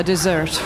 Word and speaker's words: dessert: This whole place looks like a desert. I'm sorry, I mispronounced that. This whole place looks like a dessert.